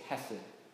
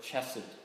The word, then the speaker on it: hesed: This word that they're trying to translate is hesed, or chesed, as you sometimes pronounce it, according to the guys that actually spend their lives studying this stuff.